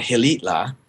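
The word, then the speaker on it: Xilitla: east down into the jungle to a place called Gilitla, which, look it up, it's spelled with an X, uh-huh.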